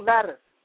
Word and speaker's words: lattice: You you was talking about he drew this crystal crystal Maddox, is that the pronunciation? Crystal lattice. Lattice. L A T T I C E.